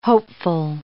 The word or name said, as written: hopeful